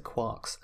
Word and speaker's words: quark: I'm going to pronounce it quarks, and this is correct, because he nicked the name from a famous doorstop and impenetrable self-appointed novel, Finnegan's Wake, which includes, alongside a whole bunch of other stuff, the phrase, "three quarks for Muster Mark." And unless you pronounce Mark as Mork, you can't pronounce quark as quark, or it doesn't rhyme.